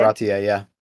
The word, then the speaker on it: Baratie: Baratie, yeah.